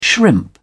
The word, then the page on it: shrimp: British English